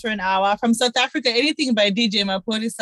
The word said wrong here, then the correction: Maphorisa: Ma- wait, why, why you say his name is DJ Mapolisa? Maphorisa. Maphorisa. Maphorisa.